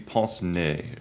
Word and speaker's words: pince-nez: An F, so it's not nez. It's like, it would be pince-nez, I think. Yeah, but it's spelled N-E-Z.